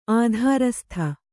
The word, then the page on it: ಆಧಾರಸ್ಥ: Kannada